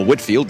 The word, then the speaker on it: Whitefield: Along with his brother Charles and others such as George Whitefield, or Whitfield, depending on your pronunciation,